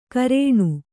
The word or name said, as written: ಕರೇಣು